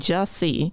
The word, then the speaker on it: Jussie: Sorry, Jesse, I've been mispronouncing it half the time. – Well, at least you're not saying Jesse, and you're saying Jussie.